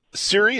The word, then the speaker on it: Ciri: Is it Siri? Is that how it's pronounced? Because you're talking to a video game idiot here. I know nothing. Is that how you say the name? C-I-R-I?